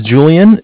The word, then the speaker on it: Julian: My name is Julian. It's spelled J-U-L-I-A-N.